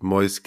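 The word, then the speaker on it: Moïse: Wobei ich auch sagen muss, dass ich Mois kenne, den ich übrigens richtig ausspreche. Ich habe es mir nochmal versichern lassen.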